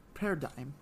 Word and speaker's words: Paradigm: But outside of that, we did get all 98 Paradigm. – Yeah, I knew that. The G is silent.